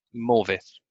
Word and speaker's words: Morfydd: Did I pronounce that right, Morfydd? – Morfydd. – Morfydd. Sorry, Morfydd Clark.